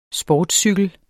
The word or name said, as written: sportscykel